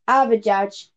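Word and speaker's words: Avdijaj: I'm sorry if this offends anyone, but I've not got a clue how to pronounce this guy's name. But okay, it's Donis Abujadj.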